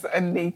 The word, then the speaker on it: Anike: It's Anike.